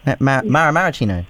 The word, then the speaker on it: Maraschino: I think Sev tried to get me to pronounce that properly last week. – Maraschino? – Maraschino. – Oh, okay. – Yeah. Right. Gotta get the flow. – Like the cherries, yeah.